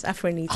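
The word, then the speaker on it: Afronitaaa: So, Afronito it's pronounced, isn't it? – Yes, Afronito.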